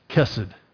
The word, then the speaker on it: chesed: And that word is chesed, and it's spelled C-H-E-S-E-D.